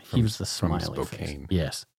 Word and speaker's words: Spokane: Which is Spokane. It's pronounced Spokane. – That's my Britishness coming out. Not knowing how to pronounce it. Is it Spokane or Spokane? – Spokane, yeah.